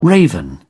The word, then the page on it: raven: British English